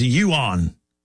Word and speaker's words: yuan: Steam Crab says the yuan is pronounced yuan.